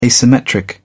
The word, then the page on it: asymmetric: British English